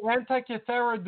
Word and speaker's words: Antikythera: The Antikythera device, it's called.